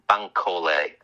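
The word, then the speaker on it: Bankolé: But I didn't believe for a second that how do you pronounce his name? Is Isaac de Bankole, Bankole? – Isaac de Bec sorry, Isaac de Bankole, French actor, yeah.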